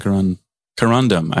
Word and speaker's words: corundum: And I think we should change the name to corundium. – Yeah. I would love some corundum. I said it wrong the first time.